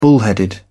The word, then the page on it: bullheaded: British English